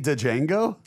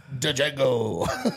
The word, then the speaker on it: Django: Did you say Django? – Django. – You pronounced the D? – With Mike, yeah, the D's never silent.